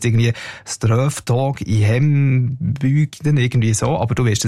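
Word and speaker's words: Strövtåg i hembygden: Ich finde, du hast die Aussprache super hergebracht. Das Lied heisst Ströftag in Hembügden, genau.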